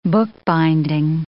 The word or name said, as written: bookbinding